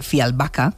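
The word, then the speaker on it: Fjällbacka: Pues resulta que los crímenes de Fialbaca, que es como se pronuncia,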